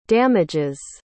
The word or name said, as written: damages